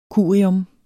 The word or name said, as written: curium